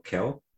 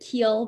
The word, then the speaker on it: Khill: Am I pronouncing it right? It is just K-H-I-L-L, Keel. – I think that counsel pronounced it Keel, but Keel? Okay. – The media seems pronouncing it as Keel, so.